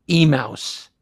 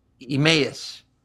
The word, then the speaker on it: Emmaus: Well, both Anita Wise and I kept calling it Emouse. And then we found out it's Emmaus.